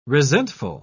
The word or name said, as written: resentful